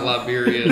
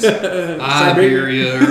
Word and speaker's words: Iberia: No, it's not Liberia. It's Iberia or something.